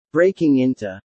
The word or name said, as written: breaking into